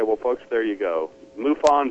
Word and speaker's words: MUFON: pronounce MUFON without it sounding like you're imitating a cow? Are you saying it correctly? – I'm saying it like I was told that it should be MUFON. – MUFON.